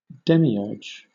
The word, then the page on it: demiurge: British English